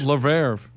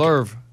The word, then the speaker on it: Louvre: And at the Louvre in Paris, I think that's how you say it, right? The Louvre? – I think. I don't know. I thought it was a luge. – That's Louvre. – No. – Okay. Louvre. – It's how it's spelled. I know. It's like Brett Favre.